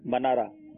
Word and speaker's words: منارہ: آپ نے یہ جواب منارہ ہوگا یا مینارہ ہوگا؟ – ہاں یہ دو سوال ہو گئے تلفظ والا. – منارہ. – منارہ جی.